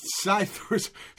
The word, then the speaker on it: psithurism: And that's psithurism. Let's start with a P. You know how in English sometimes we have a silent P, like psychiatry and shit like that. – Yeah. – This has that.